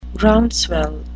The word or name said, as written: groundswell